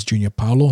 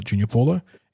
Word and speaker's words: Junior Paulo: But yeah, this uh, last week in Multicultural Round, the club put out a tweet with the boys pronouncing names, and it is Junior Paulo, not Junior Paulo.